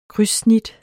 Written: krydssnit

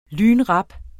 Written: lynrap

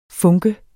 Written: funke

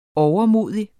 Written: overmodig

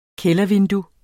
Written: kældervindue

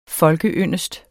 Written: folkeyndest

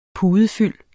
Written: pudefyld

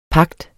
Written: pagt

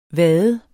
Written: vade

